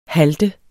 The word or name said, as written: HALTEDE